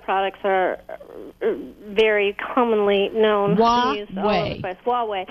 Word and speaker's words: Huawei: Yeah, my way or the Huawei. Huawei. It took me a while to know how to pronounce that, too. I think I was in China for like 3 days before I got it right.